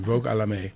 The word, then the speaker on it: Vogue à la Mer: let me get this right, Vogue à la Mer. I think is the French pronunciation, Vogue à la Mer.